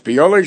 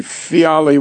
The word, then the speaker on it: Fiali: Piyali Shel Cheres, actually, the correct pronunciation would be Fiali, which is a veal, right? Same words, basically based on the Greek.